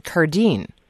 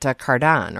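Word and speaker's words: Cardin: My distant relative, a cousin, is Pierre, we pronounce it Cardine. He pronounces it Cardan or Cardan. – Yeah, yeah, and Pierre is a distant relative of mine. We take the Italian pronunciation of that French name, but I've picked up a few items with his name attached to it because of that connection.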